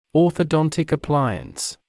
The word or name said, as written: orthodontic appliance